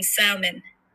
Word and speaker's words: salmon: I used to pronounce the L in salmon.